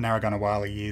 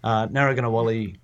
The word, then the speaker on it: Narragunnawali: Do you want to give us a big rundown of why, you know, sort of what Narragunnawali is, and I guess, you know, particularly why it's really important for services to engage with it.